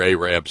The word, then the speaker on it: Arab: Yep, it did vary in your Arab society. Apparently, she's been to North Central Alabama. – That's where you pronounce A-R-A-B is Arab.